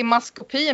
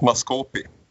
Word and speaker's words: maskopi: Hon har varit i maskopi med Carl Gustav och därför får han sitt mm. eget narrative. – Det uttalas maskopi.